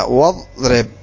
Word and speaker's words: واضرب: فإنك لا تنطق بالهمزة فيصير نطقها هكذا واضرب.